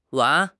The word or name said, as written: 丸